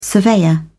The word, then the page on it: surveyor: British English